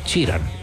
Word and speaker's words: Sheridan: El asunto es Sheridan, ¿verdad? ¿Sí se pronuncia? – Sheridan. – Ya, perdón. Entonces, gracias por la corrección.